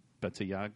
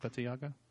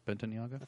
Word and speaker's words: Bentayga: perhaps to compete with the Bentley Bettiaga? Did I say that right? Is it Bentayga? Bentayga? – I think it's Bentayga. – Bentayga, yeah, probably.